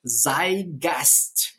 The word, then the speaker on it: Zeitgeist: Então esse termo Zeigast, Zeitgeist, né? É um termo de pronúncia alemã.